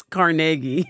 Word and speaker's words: Carnegie: Depends on where you're from. In Pittsburgh, it's Carnegie.